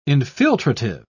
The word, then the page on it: infiltrative: British English